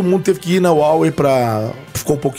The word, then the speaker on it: Huawei: A, a, corrija a pronúncia aí. Huawei. Huawei. Huawei. Huawei. Huawei. Huawei. Isso.